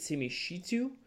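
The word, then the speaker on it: Simi Shittu: You have Simi, I'm going to mispronounce his name, but Simi Shitu from Vanderbilt.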